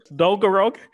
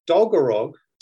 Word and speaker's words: Dolgarog: Dolgarog, and apologies to Welsh speakers, but I do think that is how it's pronounced.